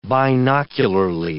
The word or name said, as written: binocularly